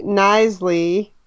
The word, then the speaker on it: Knisley: Yeah, I finished Relish, My Life in the Kitchen by Lucy Nisley, whose name I think I completely butchered the last time that we talked about this book. So it's spelled Knisley, but the K is silent.